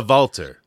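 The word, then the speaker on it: WALTER: It's probably because when people hear Valter, they spell it like when they go to their internets, they spell it with a V, even though it's a W.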